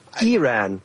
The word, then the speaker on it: Iran: It's Iran, like Israel, like Iraq.